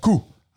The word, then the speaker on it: coup: Yeah, it's pronounced coup. – I was wondering if we were going to jump on that one. It's not the chicken coop.